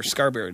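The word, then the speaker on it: Scarbury: Scarberry. Did I say it right? – Yep.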